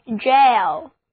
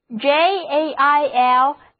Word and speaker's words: gaol: 有一个单词意思跟 prison 相同，就是 jail, J-A-I-L, jail. 当然这是美式英语的拼法，在英国英语中这个词拼写作 G-A-O-L Jail，读音相同都是 Jail，意思就是监狱。